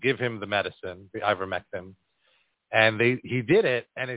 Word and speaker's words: ivermectin: and she sued them to give her husband ivermectin.